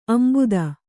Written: ಅಂಬುದ

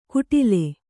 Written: ಕುಟಿಲೆ

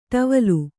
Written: ಟವಲ್

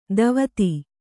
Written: ಧೋವತಿ

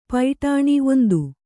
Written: ಪೈಟಾಣಿ

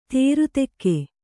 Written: ದಾರಿದೆಗೆ

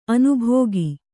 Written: ಅನುಭೋಗಿ

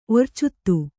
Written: ಒರ್ಚುತ್ತು

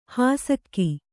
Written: ಹುಸಿಕ

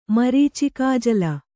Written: ಮರೀಚಿಕಾಜಲ

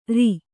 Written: ಱ

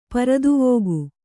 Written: ಪೃಥ್ವಿಕೆ

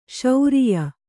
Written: ಶ್ರಾಯ

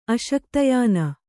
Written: ಅಶಕ್ತಯಾನ

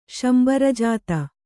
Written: ಶಂಬರಜಾತ

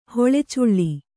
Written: ಹೊಳೆಚುಳ್ಳಿ

